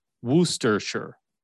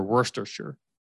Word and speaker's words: Worcestershire: Like the British English pronunciation, because I work with two people from the UK, and it's Worcestershire or Worcestershire. That's how you say it, but it's spelled Worcestershire.